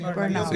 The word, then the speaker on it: burnout: Eh, para tratarlo con expertos, burnout. ¿Cómo se pronuncia así? – Burnout.